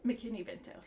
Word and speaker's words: McKinney-Vento: McKinney-Vento? – McKinney-Vento, yeah.